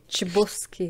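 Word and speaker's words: Chbosky: Uh, což je teda adaptace známého eh, muzik, stejnojmenného muzikálu z Broadway, uh, kdy film zrežíroval Steven Chopsky, asi se to vyslovuje, eh, který má zase... Čbosky, ne?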